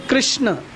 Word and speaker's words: कृष्ण: हरे कृष्ण अ है कृष्ण, लेकिन नॉर्मली लोग उच्चारण क्या करते हैं, हरे कृष्णा। कृष्णा किसका नाम है, कृष्णा किसका नाम है, फीमेल का नाम है, द्रौपदी का नाम है, समझ में आया ना।